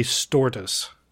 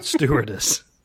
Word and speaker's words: stewardess: Everyone else also heard Craig say stortus, not stewardess.